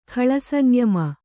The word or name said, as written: ಖಳಸಂಯಮ